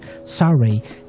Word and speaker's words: Saori: I just learned that's how you should pronounce this name. Saray, Dive Into the Mellow for Aquatic Mind.